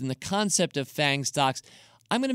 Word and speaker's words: FAANMG: It's F-A-A-N-M-G. Now, for our purposes this week, I'll be pronouncing that as FANG stocks, but F-A-A-N-M-G could be FAN-M-G, if you like.